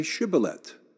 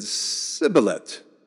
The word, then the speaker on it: shibboleth: And when he said, no, they said to him, then say shibboleth. And he said, sibboleth, for he could not pronounce it right.